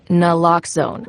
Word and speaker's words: naloxone: For the record, it's Naloxone. Nuh, like, is Naloxone addictive? Nuh. Lox, like Naloxone keeps humans safe, like locks keep bikes safe. And own, like Naloxone can save lives in our very own communities.